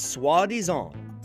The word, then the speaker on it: soi-disant: It's soi-disant. That's spelled S-O-I hyphen D-I-S-A-N-T.